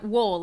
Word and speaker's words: Woll: Yeah, Woll, like bowl.